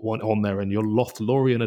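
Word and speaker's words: Lothlórien: and I don't know if I pronounce this correctly, but Lothlorien, is it? – Lothlorien. – Yeah, Lothlorien.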